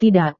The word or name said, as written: tidak